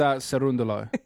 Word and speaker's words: Cerundolo: It's Cerundolo.